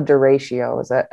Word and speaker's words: derecho: it's called a derecho. Am I pronouncing that correctly? – I think so. I think you're pronouncing it better than I would have.